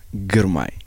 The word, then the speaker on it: Grmay: Tsgabu Gebremariam Grmay.